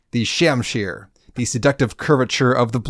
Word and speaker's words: shamshir: So yes, a high-quality pair of cobalt knuckles, a cobalt shamshir, and I hope I'm pronouncing that correctly.